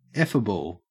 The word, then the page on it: effable: Australian English